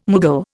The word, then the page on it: Mughal: Indian